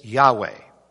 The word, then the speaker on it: Yahweh: Now, how do you pronounce, in Hebrew at least, the personal name of God? Most scholars today, I think, would say it's Yahweh. It's Yahweh.